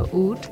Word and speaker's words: oud: So this episode, DJ A-Rob wanted to look at the oud. O-U-D, rhymes with food.